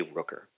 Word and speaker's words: Rooker: Rooker. – And last name, first name is spelled S-H-A-W-N-K as in kangaroo, Rooker, R-O-O-K-E-R.